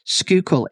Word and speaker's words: Schuylkill: Schuylkill is the correct pronunciation of this word. It is actually spelled S-C-H-U-Y-L-K-I-L-L.